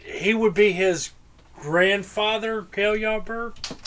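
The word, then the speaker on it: Cale Yarborough: I'm trying to kill Yarberg. – Who? – Who the fuck is that? – Kel Yarborough.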